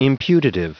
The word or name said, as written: imputative